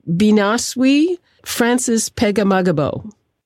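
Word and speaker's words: Binaaswi Francis Pegahmagabow: Is it Binaswi Francis Pegamagabo? – Yes, exactly. So Binaswi was a veteran from the First World War, and the most highly decorated Indigenous soldier in Canada's history.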